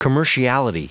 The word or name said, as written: commerciality